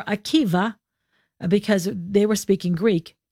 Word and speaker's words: Akiva: It's spelled with a B or a V, Rabbi Akiva or Akiba. So it's a Vita, but it's the Greek B is pronounced with a V.